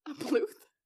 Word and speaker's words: Puth: Well, that's by Charlie Puth. – Puth? – Is that how you say his name? – Puth. – Puth? – Puth. – Puth? – Like Booth.